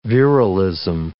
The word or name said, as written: virilism